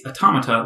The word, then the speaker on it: Automata: Almost everyone calls it Automata, but it's Automata, like automaton.